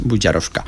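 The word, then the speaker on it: Bužarovska: vol dir que és una, com si era una jota, no? Se pronuncia com una jota, és Buzarovska.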